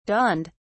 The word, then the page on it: dawned: British English